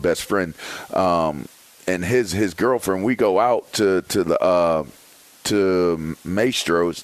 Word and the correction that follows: Mastro's: Did I say it right? Mastro's. We go to Mastro's, right?